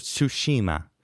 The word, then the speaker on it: Tsushima: Tsushima. I think you have to say it fast. You can't pronounce it all. Ghost of Tsushima.